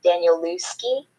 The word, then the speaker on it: Danielewski: Danielewski.